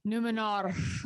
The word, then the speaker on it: Numenor: Guys, I think you're saying it wrong. Yeah. I think it's Numenor. Numenor. Numenor.